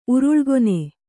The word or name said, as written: ಉರುಳುಗಣ್ಣಿ